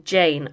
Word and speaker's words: Jain: also by a French pop star called Jane, which is spelled J-A-I-N.